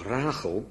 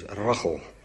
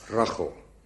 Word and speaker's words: Rachel: Want zo spreek je dat, mensen vragen dat, het is toch Rachel? Nee, het is Rachel. Hè? Dat is de gewone Nederlandse uitspraak, Rachel. Met die CA, of hebben we het daar al eens over gehad? Nee, hè? Rachel, ja. Kan het ook niet helpen, maar zo spreek je dat uit.